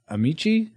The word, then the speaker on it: Ameche: And Babo confirms your pronunciation of Ameche, I think that's what he said.